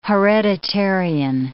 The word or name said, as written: hereditarian